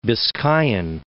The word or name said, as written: Biscayan